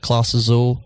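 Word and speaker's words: Clase Azul: I always say it wrong, but I think it's like Claus Azul. – Yeah. – Is that right? – Yes.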